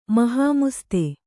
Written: ಮಹಾಮುಸ್ತೆ

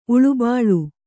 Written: ಉಳುಬಾಳು

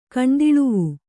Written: ಕಣ್ದಿಳುವು

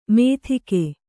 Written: ಮೆತ್ತಿಗೆ

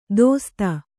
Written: ದೋಸ್ತ

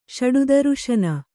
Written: ಷಡುದರುಶನ

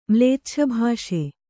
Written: ಮ್ಲೇಚ್ಛಭಾಷೆ

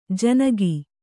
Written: ಜನಿಕ

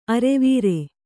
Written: ಆರವಾರ

